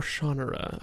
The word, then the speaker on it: Shannara: I will say this for the Shannara series, or Shannara, I guess, as Terry Brooks calls Shannara? – It. – Is it Shannara? – That's how he pronounces it.